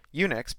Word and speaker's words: Unix: Unix, pronounced Unix, is a powerful, flexible computer operating system for multi-user, multitasking computers.